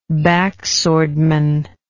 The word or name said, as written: backswordman